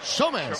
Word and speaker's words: Sommer: Comer. – No, Sommer. – Sí, sí, Sommer nos dijo a nosotros. – Que se pronuncia pues Sommer.